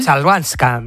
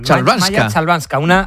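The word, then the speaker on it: Chwalińska: Joder, ¿cómo se dice la es complicada, ¿eh? Chabalinska. – ¿Eh? Chalvanska. – Chalvanska. – Maya Chalvanska. Una polaca que es la número 114 del mundo y que ha jugado un partido extraordinario.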